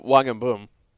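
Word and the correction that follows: In-beom: Is the am I even saying that correctly? – In Boehm, I believe. – In Boehm.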